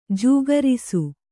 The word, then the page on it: ಜೋಗರಿಸು: jōgarisu